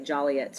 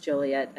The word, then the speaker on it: Joliet: And did I say it right? Because I know people in Joliet get really mad when I say Joliet. So I'm going to say Joliet as well, but I know it's Joliet.